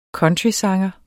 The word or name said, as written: countrysanger